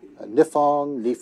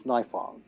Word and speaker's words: Nifong: My last name is frequently mispronounced. I have been called Nifong. There are many other things that I don't even want to repeat for you, but the way we pronounce it, the way my family has always pronounced it, is Nifong.